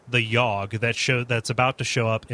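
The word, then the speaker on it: Yawhg: a game called The Yogg.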